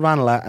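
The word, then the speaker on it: Ranelagh: No, it's called Ranala, and then sometimes people say Renla, and then they think it's posh to say Ranala, which is chilly because that's actually just the correct pronunciation.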